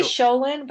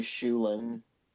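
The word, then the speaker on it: Schoelen: I say Sholin, but I know Nate did a popcorn episode and you guys talked a lot about how to say her last name. And is it like Shulin? – Yeah, it's actually Shulin.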